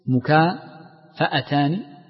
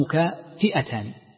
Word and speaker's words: مكافأتان: في نطقها هناك نطقان، إما بفتح الفاء أو بكسرها، مكافأتان أو مكافئتان، وكلا النطقين صحيح، ولكن المحدثين ينطقونها بالفتح ويختارون ذلك.